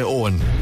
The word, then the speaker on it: Eoghan: Now, see, Owen spells it E-O-G-H-A-N.